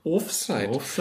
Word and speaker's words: Offside: Weil da standen auch 4 Schalker oder 4 Königsblaue im Offside. Betonung auf der ersten Silbe. – Offside.